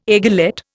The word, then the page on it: aiguillette: Indian